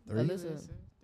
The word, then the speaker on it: Alyssa: My real name is like, what, two syllables? – Alyssa? – Three? Alyssa. Three. Yeah, three.